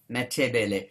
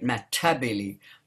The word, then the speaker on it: Matebele: So I, I, get a little bit pretentious sometimes, and I will I don't like spelling it Matabele, I spell it matebele because that's actually the correct way of saying it.